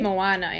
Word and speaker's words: Moana: There's one movie in the park in Hamlin Park, which is in East Aurora, and they're showing Moana. That's how you say it, right? – Moana, yes. Moana.